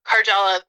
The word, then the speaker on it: Karjala: It's just my name, Jessica Karjala.